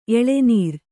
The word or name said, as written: ಎಳೆನೀರು